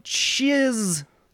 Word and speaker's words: Chiz: Chiz Lily Tits. Parenthetical rhymes with jizz.